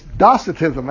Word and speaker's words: Docetism: And I actually mispronounced it in the lesson, and I passed it on to you. I'm sorry. It's Docetism.